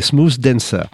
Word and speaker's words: Smooth Dancer: J'allais prononcer le C à l'espagnol, c'est-à-dire la langue entre les dents. Mais non, mais non, c'est Smooth Dancer.